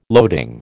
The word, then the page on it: loading: American English